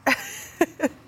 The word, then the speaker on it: Joe Tsai: Joe Tsai. – Okay, here we go. Joe Tsai and Sean Marks.